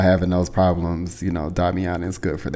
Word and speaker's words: Damiana: But the tea I'm sipping on, or will be sipping on in a minute, today is Damiana. I think it's pronounced Damiana. I think it's Damiana. It's Damiana tea. It sounds like Tatiana.